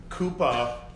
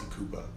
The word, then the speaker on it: Kupah: I changed it when I was like 25, 26 to Kupah Lee James. I reversed it and changed the spelling of my last name phonetically to Kupah.